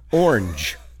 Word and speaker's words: orange: Orange. Did I say it right that time? – You said it my way, yeah. – That car is orange. Nope, it's so hard to say in one syllable.